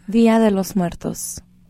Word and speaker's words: Día de los Muertos: Día de los Muertos.